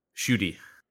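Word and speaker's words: Ncuti: Is that how you say his name? – Shuti. Is how I've heard Shuti Gatwa.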